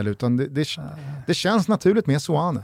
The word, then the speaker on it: Seoane: S-E-O-A-N-E. Det är svårt att hitta något annat än ett fonetiskt Seoane.